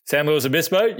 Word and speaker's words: San Luis Obispo: It was a very cool town, and it was called San Luis Obispo. Did I pronounce it right? – San Luis Obispo.